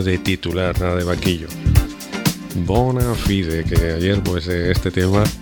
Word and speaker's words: Bona Fide: Burn Me, More Be Nights, de la banda norteamericana Bona Fide, que como es latín, pues se pronuncia así, Bona Fide.